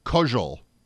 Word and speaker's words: Koziol: It's spelled exactly the same, but he pronounces it differently. – Really? – Yes. – Yes. – Yeah. I've never met this guy, but hearing the other guys in the league talk about him, he apparently pronounces it Koziol. Like that.